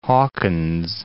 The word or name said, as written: Hawkins